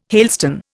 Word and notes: hailstone: Indian

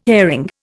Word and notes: airing: Indian

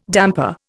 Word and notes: damper: Indian

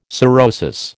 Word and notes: sorosis: American English

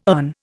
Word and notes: earn: Indian

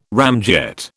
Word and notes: ramjet: British English

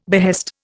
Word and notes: behest: Indian

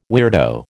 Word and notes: weirdo: American English

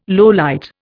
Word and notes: lowlight: Indian

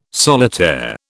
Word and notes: solitaire: British English

